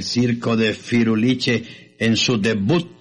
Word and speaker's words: debut: Pero la verdad es que el sábado es el debut del circo. – Y además no se dice debut. – ¿Cómo se dice? – Se dice debut. Ah, con T.